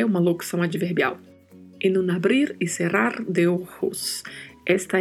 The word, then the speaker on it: abrir: Na sequência, o verbo abrir, verbo de terceira conjugação, que tem o mesmo significado e é escrito do português, no entanto, a pronúncia tem a particularidade do R aí com o vibrato. Não podemos nos esquecer disso.